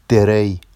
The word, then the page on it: derej: Vafsi